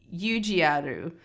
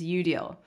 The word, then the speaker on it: Eudial: So the Japanese pronunciation is Yujiaru, uh, but the English pronunciation is Eudial.